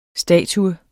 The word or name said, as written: statue